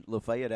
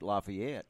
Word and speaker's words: Lafayette: You know, it's kind of – Lafayette, Alabama, is not far from here, but most people would pronounce it Lafayette. And but it's actually the correct pronunciation for the people here would be Lafayette.